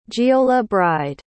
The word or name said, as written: giollabrighde